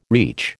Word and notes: reach: American English

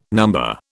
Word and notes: number: British English

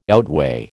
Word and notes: outweigh: American English

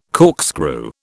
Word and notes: corkscrew: British English

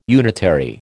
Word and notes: unitary: American English

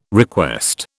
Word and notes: request: British English

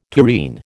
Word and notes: tureen: American English

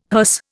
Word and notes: hearse: Indian

